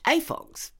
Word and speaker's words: AFOGS: AFOGS, standing for Adult Fans of Girls' Stories and pronounced AFOGS.